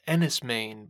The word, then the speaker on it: Enys Men: First up is Ennis Main by Mark Jenkin. And that's E-N-Y-S-M-E-N. That's a Cornish pronunciation. Took me quite a while to realize exactly how to say it.